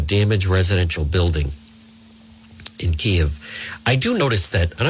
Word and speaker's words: Kyiv: This is how you pronounce it. – Kyiv.